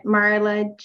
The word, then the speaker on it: Marla: Well, I'm on Instagram at Marla J. Tseng. I'll spell that. So M-A-R-L-A-J, and then I'll spell my last name T-S-E-N-G.